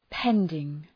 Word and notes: pending: ‘pendıŋ

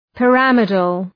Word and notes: pyramidal: pı’ræmıdəl

